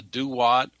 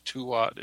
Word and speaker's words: Duat: The Egyptians called it the Duat, or sometimes Tuat. It's spelled D-U-A-T. And the Hopi have a similar word. It's spelled T-U-A-T, and Tuat, which means the place where mystical visions are achieved.